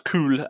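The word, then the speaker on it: Kül: So yeah, pronounce Kul, which is K-U with umlaut L, if you're looking it up, or Ashes.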